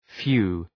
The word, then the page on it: phew: fju: